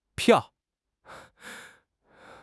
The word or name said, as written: piao4